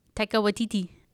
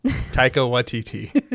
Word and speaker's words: Waititi: Taika Waititi. – Yes. Taika Waititi. Or YTT. Like Waikiki, only with T's.